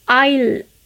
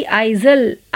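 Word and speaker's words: aisle: आइल A I S L E यहाँ पर आइल ना कि आइजल आइल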